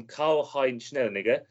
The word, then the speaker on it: Karl-Heinz Schnellinger: There's a 90th minute equaliser from Karl-Heinz Schnellinger, who was... that sounds awful. I'll start again. That sounds terrible. How do you say that name? That's awful. That's a whole... – That is how you say it.